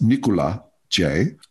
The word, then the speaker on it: Nicolas-Jay: And I should, by the way, it's it's spelled Nicholas J. For us, you know, um, Americans who are trying to find this label, pronounced Nicola J.